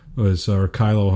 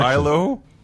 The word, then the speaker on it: Kilo: It sounds like this release, the Kilo. Kylo, however you pronounce it, is incremental, but it must have a direction. – Kylo. – Well, K-I-L-O, right? – Kylo. Yeah, Kylo? I'm with Kilo. I'm going to go metric on it there.